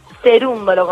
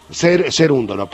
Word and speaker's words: Cerúndolo: Perdón que por allí, a ver, no sé la pronunciación, he escuchado en distintos medios Cerúndolo, por otro lado Cerúndolo, ¿cómo es en realidad el apellido? – Cerúndolo, con tilde en la U. – Cerúndolo, perfecto.